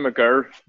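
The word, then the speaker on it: Mager: Magger, M-A-G-E-R, I think it's spelled. – Oh, sorry, Damien McGurr.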